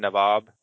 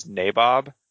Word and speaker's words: nabob: I am fully aware that the actual pronunciation of the word is Nabob, but that takes longer to say, and I've just gotten used to saying Nabob, so I'm going to probably keep doing that.